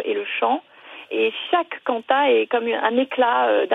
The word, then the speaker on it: Quanta: C'est quoi ces cantats, ces mélodies sur des textes de Dominique Lambert? – Alors, on prononce Canta d'ailleurs, Q-U-A-N-T-A.